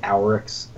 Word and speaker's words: Oryx: We see Oryx, it means long thought, and then in later cards he's actually called long thought before he becomes O-R-Y-X, Oryx. Like this is like usually pronounced like Auryx, A-U-R-R-Y-X, just so there is a difference.